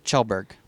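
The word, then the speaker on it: Kjellberg: I don't know how to say his last name. – Chelberg. – Chelberg? – Chelberg. – That's really how he says it.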